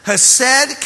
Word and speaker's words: Hesed: That word and that concept is spelled H-E-S-E-D, pronounced Hesed.